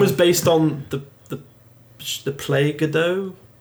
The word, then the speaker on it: Godot: Yeah, that's using, I believe it's pronounced, it depends who you ask, I believe it's pronounced Godot, G-O-D-O-T. Some people say it's Godot, other people say it's Godot.